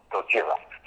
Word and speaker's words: Gojira: It is the original pronunciation of Godzilla, Gojira.